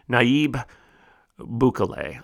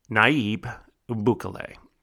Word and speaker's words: Nayib Bukele: Nayib Bukele, I'll spell it N A Y I B B U K E L E.